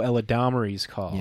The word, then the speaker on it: Eladamari's Call: No, Latimer's Call. You're thinking of Maria's Call. – Oh, Eladamari's Call.